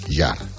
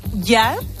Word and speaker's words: Jarre: Jean-Michel Jarre o Jarre? – No, Jarre sería con un acento en la E. ¿Lleva acento? – No. – Jarre. – Pues Jean-Michel Yar, que hoy cumple 75 años de tu quinta.